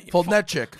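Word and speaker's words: Foltynewicz: I thought it was Fulton-ish. Fulton-ish? – Okay. Like, you know, Fulton-etchik.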